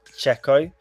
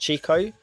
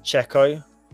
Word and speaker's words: Checo: So it's Checo. It's not Chico. It's not Checho. It's not whatever the names have been called in many emails. It's Checo.